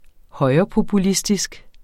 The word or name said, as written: højrepopulistisk